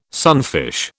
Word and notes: sunfish: British English

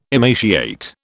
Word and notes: emaciate: British English

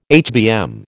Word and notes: HBM: American English

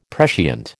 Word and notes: prescient: American English

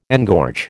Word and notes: engorge: American English

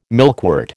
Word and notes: milkwort: American English

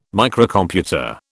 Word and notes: Microcomputer: British English

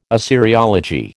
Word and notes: Assyriology: American English

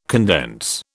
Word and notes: condense: British English

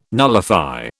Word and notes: nullify: British English

